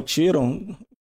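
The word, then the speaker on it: Quíron: Segundo a mitologia, Sagitário, um, o centauro Quiron, né? O Tiron, dependendo do modo como você pronuncia, era um centauro ali que não era é, exatamente só um monstro.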